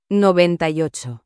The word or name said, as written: 98